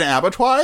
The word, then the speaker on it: abattoir: Can you look up what an abattoir is? – Okay. – A-B-A-T-T-O-I-R. I'm pretty sure I'm pronouncing that. – What is an abattoir? A-B-A-T-T-O-I-R? – Yeah. Oh, I did say it right.